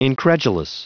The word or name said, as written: incredulous